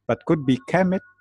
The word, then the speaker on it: Kemet: And to explain why I didn't write the vowels, like I pronounced the vowels, Kemet, E-K-E-M-E-T, because we are not very sure about the vowels when we read the Egyptian language. We are very sure about the consonant.